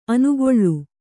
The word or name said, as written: ಅನಾಕುಳ